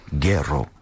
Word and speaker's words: Guero: I think the correct pronunciation is Guero.